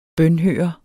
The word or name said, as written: bønhøre